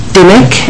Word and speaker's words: Dimmick: My name is Kimberly Dimmick. – I'm sorry, I didn't get your last name. – Dimmick.